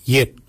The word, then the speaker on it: yed: N-A-N-G-A-G. Nanak. Nanak is your neck. Chin. We say yet. Yet for chin. Yet is spelled Y-E-D.